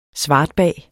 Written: SVARTBAG

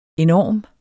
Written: enorm

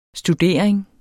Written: studering

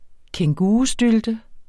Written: kængurustylte

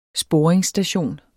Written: sporingsstation

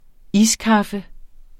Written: iskaffe

